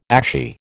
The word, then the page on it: ashy: American English